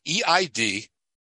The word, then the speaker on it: Eid al-Fitr: At the last day of Ramadan, and it's spelled EID-AL-FITR, Eid al-Fitr, I think is the way it's pronounced.